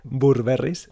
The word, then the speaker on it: Burberry: de, en español se pronuncia Burberry's, ¿De qué? Burberry's, ¿Cómo se pronuncia bien? Burberry. – Burberry.